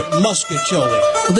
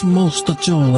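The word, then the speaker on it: mostaccioli: Now, a lot of people in St. Louis pronounce it muscacchiole. – They don't know what they're talking about. It's mostacchiole. I'm going to tell you, that's the way I pronounce it.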